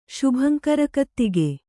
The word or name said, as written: ಶುಭಂಕರಕತ್ತಿಗೆ